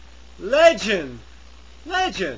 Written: legend

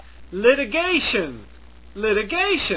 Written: litigation

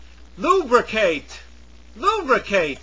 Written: lubricate

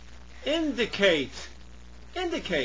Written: indicate

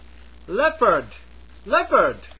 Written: leopard

